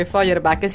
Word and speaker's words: Feuerbach: Io ho trovato questo testo di Feuerbach. – Sì. – Sì, se così si pronuncia, credo di sì. – Feuerbach.